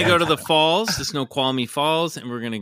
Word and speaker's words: Snoqualmie: Snoqualmie, I believe is how you say it. Snoqualmie. – Wow. It's S N O Q U A L M I E. Snoqualmie.